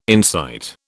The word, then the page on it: incite: British English